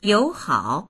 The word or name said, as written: you3hao3